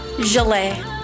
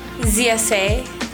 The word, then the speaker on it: Zsalei: Zsalei. – Zsalei. – Yes. – Y'all see how fancy that sounds? Like this. – How do you spell that? – Zsalei. So the Zsa is Zsa and then Le.